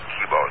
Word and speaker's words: Kibo: Well, attached to this, you know, inside this Japanese Kaibo module, sometimes they pronounce it Kibo, it's K-I-B-O.